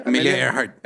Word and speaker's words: Amelia Earhart: Es que no sé cómo se pronuncia. – Amelia Earhart. – Earhart.